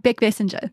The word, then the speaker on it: Beckbessinger: Did I say that right? – Close enough. Beckbessinger. – Beckbessinger. – Yeah, close enough.